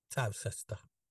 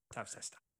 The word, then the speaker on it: Towcester: And it's not spelt like Toaster either, is it? – Towcester. – Towcester. – Like Leicester. And Gloucester, yeah.